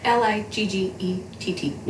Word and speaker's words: Liggett: So, but this one was called The Gracier, and it's by Kim Liggett. I don't know if I'm saying that right. Probably not. – It sounds right. Liggett.